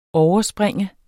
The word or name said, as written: overspringe